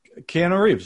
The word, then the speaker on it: Keanu Reeves: Keanu Reeves, that's right.